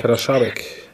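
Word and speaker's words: Kadeřábek: Oder wie spricht man ihn aus? Kaderabek, Karaschabek. – Karaschabek.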